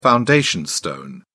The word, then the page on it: foundation stone: British English